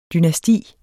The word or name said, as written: dynasti